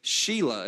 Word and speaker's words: Shelah: And she gave birth to a third son and named him Shelah. It's actually how it's pronounced. So this kid gets a name that sounds like a girl name.